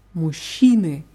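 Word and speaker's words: мужчины: Muschine. Hier ist die Betonung auf i. Muschine.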